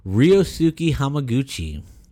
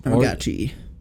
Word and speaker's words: Ryosuke Hamaguchi: Ryosuke Hamaguchi. – Yep. – Um, Hamaguchi.